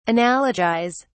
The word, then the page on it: analogise: British English